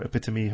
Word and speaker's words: epitome: He's got the epitome or epitome. How do we pronounce that these days? – Epitome. – There we go.